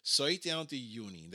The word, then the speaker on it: Sautjánda júní: Sautjánda júní, that's how you say it in Icelandic, and it's a great day for everybody in the family.